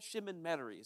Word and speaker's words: Chemin Metairie: It's right there on uh, Chemin Metairie Parkway, for all of those who don't know how to really say that word, Chemin Metairie is how you say it.